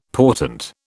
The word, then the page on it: portent: British English